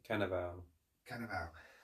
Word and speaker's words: Cannavale: Cannavale.